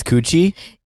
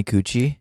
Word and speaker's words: Kouchi: Well, so I think that there was a better way to pronounce it, but we're so like Americanized. That's how my dad pronounces it, but it's spelled K-O-U-C-H-I. So I think it's supposed to be like Koche.